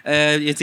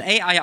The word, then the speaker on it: AI: Ihr seht es bereits auf dem Monitor, nämlich I, The Somnium Files. Man will es AI aussprechen, es wird aber tatsächlich I ausgesprochen, weil dieses Wort I direkt 3 Bedeutungen hat, was sehr gut dieses Spiel zusammenfasst.